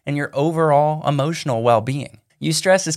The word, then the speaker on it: eustress: It's called eustress. It's spelled E-U and then the word stress.